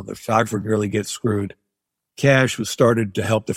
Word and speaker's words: Cache: It's spelled C-A-C-H-E. So it looks like cachet, but it's pronounced Cash Agency.